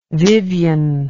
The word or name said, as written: Vyvyan